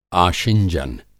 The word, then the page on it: आशिंजन: Hindi